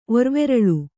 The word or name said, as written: ಒರ್ವೆರಳು